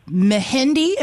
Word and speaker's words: Mehendi: Of course, I want to celebrate with you all, but the Mehendi, am I saying that right? – Yes.